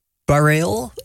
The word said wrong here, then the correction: Bareilles: Bijna goed. Dit is waar ik op gehoopt had. Sarah Bareilles. – Bareilles? – Ja. – Oh. – Ja, schijnlijk Sarah Bareilles zeggen.